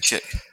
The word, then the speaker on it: Chic: Oh, it's actually Chick. – It's actually Chick, yeah, but it's spelt like as in Chic.